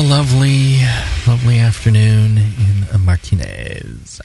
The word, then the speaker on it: Martinez: It's pronounced Martinez.